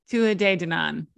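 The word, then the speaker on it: Tuatha Dé Danann: Tuatha De Danann. Is that better? – Tuatha De Danann. Yeah, that's how they said it. So I'm going with them.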